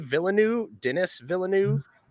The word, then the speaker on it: Villeneuve: And I'm probably going to butcher his name, but is it Denis Villeneuve? – Yeah, Denis Villeneuve or Denis Villeneuve.